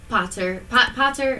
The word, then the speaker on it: Potter: Potter is correct. – Potter? – Just like Harry Potter.